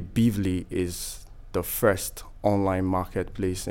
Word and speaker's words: Beavly: Am I pronouncing it right? Is it Bizly? – Yes, you are. Bizly. – Okay. Now spell it for me. – Bizly.